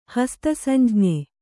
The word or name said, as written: ಹಸ್ತಸಂಜ್ಞೆ